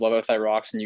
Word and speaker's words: levothyroxine: And let's talk about thyroxine, levothyroxine. Did I pronounce that right? Levothyroxine? – Levothyroxine. That's correct. Yeah.